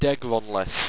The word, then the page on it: degronless: Owstwecian